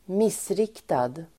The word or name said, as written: missriktad